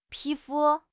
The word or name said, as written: pi2 fu1